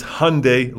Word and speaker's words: Hyundai: how do you pronounce Hyundai? And he said, it's Hyundai like Sunday. And so that's the correct way.